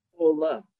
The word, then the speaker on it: Bohle: Sue, I know it's not Bohle because I think you taught me that. It's Sue Bohle? – Bohle.